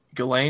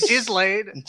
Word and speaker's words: Ghislaine: Ghislaine.